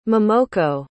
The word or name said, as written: momoko